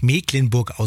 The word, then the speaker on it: Mecklenburg: So, und für alle Skeptiker, die immer noch sagen, aber Mensch, Mecklenburg, das wird doch mit CK geschrieben, da muss es auch so heißen. Gleich um 9.20 Uhr erklärt uns Björn Berg vom Kreis Agrarmuseum Dorf Mecklenburg im Landkreis Nordwestmecklenburg, warum es dennoch Mecklenburg ausgesprochen wird.